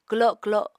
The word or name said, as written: kwlo kwlo